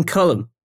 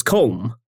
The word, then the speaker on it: Colm: They're like, why does he always call him Cullen? – It's because that's his name. – It's like, that's how you say it. Trust me. Like, I thought it was Colm for ages, but it's not.